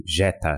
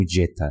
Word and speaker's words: Geta: É latim e se escreve G-E-T-A, tipo Jeta. E todas as fontes que eu vi. Porque faz tempo que eu fiz minhas aulas de latim, então todas as fontes que eu vi de como pronuncia isso, falam Jetta.